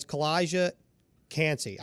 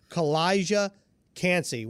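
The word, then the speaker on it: Calijah Kancey: And the guy I want to talk about is Kalijah Kancey. I say that right? – You got it. – Man, that is a tongue twister. Kalijah Kancey. One's a C in Kalijah, one's a K in Kancey.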